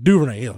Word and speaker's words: Duvernay: He's like, how do you say the kid's last name? I was like, Duvernay. He's like, Duvernay. – There you go.